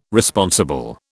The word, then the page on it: Responsible: British English